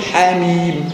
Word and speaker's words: حم: إنما هنا لا تنطق تقول حاميم، ولو أن يعني اللفظ الألفاظ أو الحروف واحدة وشكل الحروف هو شكل الحروف، ولكن اللي يميز بين النطق والنطق إيه؟